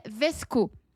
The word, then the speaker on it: VSCO: Il y a une autre application qui est sensiblement un peu comme Instagram, qui s'appelle Vesco. Ça s'écrit V-S-C-O.